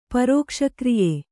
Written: ಪರೋಕ್ಷಕ್ರಿಯೆ

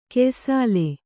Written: ಗ್ಯಾಸೊಲೆ

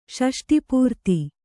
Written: ಷಷ್ಟಿಪೂರ್ತಿ

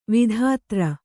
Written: ವಿಧುಧರ

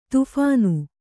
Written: ದಫನ

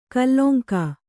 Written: ಕಲಂಕಿ

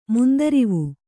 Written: ಮುಂದರಿವು

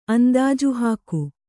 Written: ಅಂದಾಜುಹಾಕು

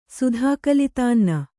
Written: ಸುಧಾಕಲಿತಾನ್ನ

